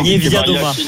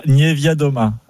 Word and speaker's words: Niewiadoma: Niewiadoma. – Kasia, voilà. Niewiadoma.